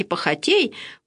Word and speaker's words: похотей: И вслед похотей, по-русски другое будет ударение, а по-церковнославянски похотей, плоти моей я ходив.